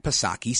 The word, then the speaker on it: Psaki: Speaking of reporters at the White House on Friday, Jen Psaki, she acknowledged, and you know her, Jen Pacircle Back Psaki, silent P.